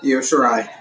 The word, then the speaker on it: Io Shirai: But the highlight of the night was obviously the Io Shirai. That's her name, correct? – Mm-hmm. – Io Shirai.